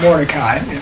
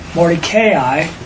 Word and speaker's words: Mordecai: tonight the sharing is going to be on the providence of Yahweh, Mordecai, and it's not Mordecai, it's really four syllables, Mordecai and Esther.